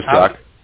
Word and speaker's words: Jack: Yeah, first name is Jack. It's Jack. – J-A-C-K.